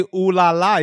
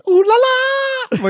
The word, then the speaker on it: ooh la la: You know, he doesn't even say, he puts the emphasis on the wrong syllable. He doesn't even say ooh la la. He goes ooh la la. Like it's the last one. – Ooh la la.